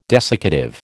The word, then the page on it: desiccative: American English